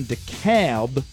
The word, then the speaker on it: DeKalb: We're going to be in DeKalb, Illinois. That's how you pronounce it. – DeKalb? – D-E-K-A-L-B. – Oh, I thought it was DeKalb.